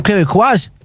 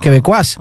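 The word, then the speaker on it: Québécois: Y estos señores son quebecuas. – ¿Ah, se dice así? – Sí, son de Quebec. Los quebecuas.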